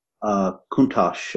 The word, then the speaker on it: Countach: While we're at it, the Lamborghini Countach, is it pronounced Countach, by the way? – Countach.